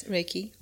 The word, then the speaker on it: Reiki: Yes, Reiki.